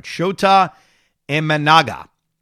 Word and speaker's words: Shota Imanaga: Now, one of the other now whats is Shoto Imenga. Uh, let me pronounce his name right. Shota Imanaga, who is another Japanese pitcher.